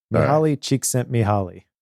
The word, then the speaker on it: Csikszentmihalyi: Mihaly Csikszentmihalyi. I think that's how you pronounce it. I know some people say it Mihai, Csikszentmihalyi. I've watched many different videos and versions of people trying to pronounce this name, but this is kind of the godfather of flow, and we'll just call him Mihaly.